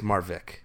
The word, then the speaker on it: Marwijk: Yeah, I think it's Marvik.